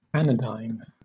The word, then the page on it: anodyne: British English